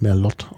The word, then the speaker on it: Merlot: Merlot zum Beispiel, der übrigens falsch ausgesprochen wird. Da müsste man eigentlich Merlot aussprechen. – Tatsächlich? – Ja. Naja, französisch ist Merlot schon richtig, nur es ist kein französisches Wort. Es ist okzitanisch. Merlot ist die kleine Amsel. Also Merlot ist die Amsel und Merlot dann das Diminutiv, Diminutivform davon.